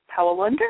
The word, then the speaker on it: Powell-Lunder: Thank you, Dr. Powell-Lunder.